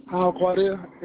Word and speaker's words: Alquadr: Alquadir, A-L-Q-U-A-D-R. – Can you repeat that for me? I didn't catch that. – A-L-Q-U-A-D-R.